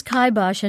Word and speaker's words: kibosh: And it is kibosh and not kibosh. I always thought it was kibosh.